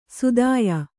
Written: ಸುದಾಯ

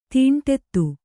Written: ದಂಡಿತ

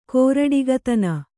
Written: ಗಾರುಡಿಗತನ

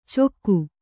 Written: ಚೊಕ್ಕು

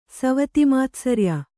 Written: ಸವತಿಮಾತ್ಸರ್ಯ

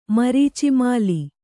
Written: ಮರೀಚಿಮಾಲಿ

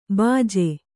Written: ಭೋಜ

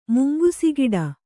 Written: ಮುಂಗುಸಿಗಿಡ

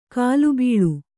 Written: ಗಲಿಬಿಲಿ

